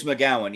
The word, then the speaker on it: McGowan: Is it Rose McGowan? – Rose McGowan, yeah. – Okay, I said it right.